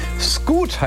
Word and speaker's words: Scoot: Scoot heißt der Mann. Ja, so ein bisschen wie Scooter, nur noch deutscher ausgesprochen und kürzer.